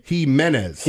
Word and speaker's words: Jimenez: How do you pronounce it again? – Jimenez.